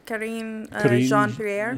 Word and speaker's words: Karine Jean-Pierre: Karine Jean-Pierre.